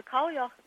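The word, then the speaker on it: Cailleach: Well, let's talk about the old woman goddesses who, I may mispronounce this, but I'll do it my best, was called Kalyak. – Kalya, yeah, the Kalya. – Oh, Kalya, okay.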